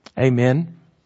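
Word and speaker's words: amen (ἀμήν): Amen. It's actually pronounced amen in the Greek.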